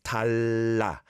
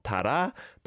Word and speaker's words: talla: Talla. So you got the sound at the bottom of the first syllable. Talla, talla. Not tara, but talla.